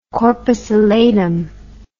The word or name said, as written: corpus allatum